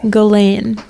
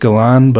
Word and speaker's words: Ghislaine: Ghislaine. – I've heard it also pronounced Galan, but I'm just going to say Ghislaine because we live in Minnesota. – So. Okay. Well, Ghislaine Maxwell,